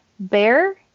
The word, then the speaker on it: Baehr: Bear is spelled in a unique way, so I'm going to spell that. It is B-A-E-H-R, which is our last name. Awesome. So a little bit of a pun there.